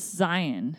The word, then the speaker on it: Zion: Because it's Zion. – Zion. – Like lion.